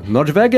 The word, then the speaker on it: Nordweg: É Nordweg que fala, né? Eu tô falando errado. Eu diria, como é alemã, Nordweg é, é o caminho pro norte, o caminho para cima.